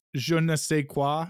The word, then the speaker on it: je ne sais quoi: So when The Dead Zone came out, less subversive, but still had that weird Cronenberg kind of je ne sais quoi. Am I saying that correctly? – Yes.